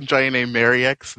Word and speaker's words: Jon-Enée Merriex: Hi, I'm John A. Mariex, the associate producer for Sword in the New World. – Oh, see, I did that long E. Oops.